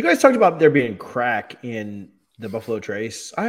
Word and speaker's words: craic: And in Ireland, they have a word, C-R-A-I-C, and it's pronounced crack, you know, just the same way as we pronounce our C-R-A-C-K.